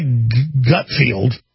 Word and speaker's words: Gutfeld: And that famous video that got released this week of him crying, well, Greg Gutfeld, that's how you say his name,